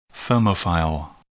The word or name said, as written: thermophile